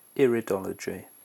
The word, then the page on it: iridology: British English